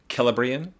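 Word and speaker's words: Celebrían: I'm assuming because the other sea names in Elvish are pronounced with a K sound, it's Celebrion.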